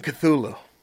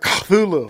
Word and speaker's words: Cthulhu: And I should say that Cthulhu's only the way that humans say it, because its actual name cannot be pronounced with a human tongue, according to H.P. Lovecraft. – Fucking Lovecraft. – It was supposed to be more like Cthulhu, like a cop.